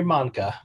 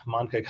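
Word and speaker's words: Mahncke: Yeah, my name is Henry Monnicke. Monnicke kind of rhymes with Tonka, like a Tonky truck, or Willy Wonka, the chocolate factory.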